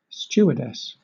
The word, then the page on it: stewardess: British English